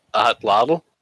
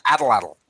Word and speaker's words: atlatl: It's atlatl. It's my ranged weapon. – Right. Oh, it's an atlatl. – Yes. – Atlatl. That's a fun word to say. Atlatl.